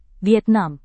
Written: vietnam

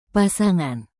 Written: pasangan

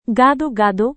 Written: gado-gado

